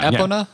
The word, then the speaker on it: Epona: Like, when I would get on and ride, what was the pony's name? Eponia? – Epona? – Epona. – Epona, yeah. I think, is the correct perhaps pronunciation.